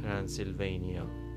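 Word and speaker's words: Transylvania: آنچه میتوان گفت اگر علاقه وجود داشته باشه به تلفظ صحیح زبان انگلیسی ترانسیلوینیا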